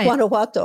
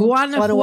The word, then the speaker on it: Guanajuato: From, say Guanajuato. Oh, my grandparents why. came from Guanajuato. Guanajuato.